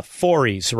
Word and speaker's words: Forys: Matt, now you say it like Forries, right? Am I saying it right? – That's correct.